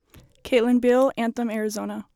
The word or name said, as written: Kaitlyn